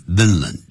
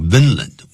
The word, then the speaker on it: Vinland: 的探测啊， this region 就是探看这个区域， region 就是区域， he named it, 他就把它命名为 Vinland, 叫做这个 Vinland 文兰这样子，不要讲芬兰，还只有现在有这个国家的芬兰，大家都知道，那是 F 开头的，这边是 Vinland。It is assumed, 就是一般猜测或者推断叫 assumed。这个 assume 在英式念法念 assume 啊，其实在美式 American English 会念成 assume。Assume 代表推断，没有经过证明啊，这样的推断。那么就用 assume 这个字。